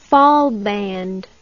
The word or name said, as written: fahlband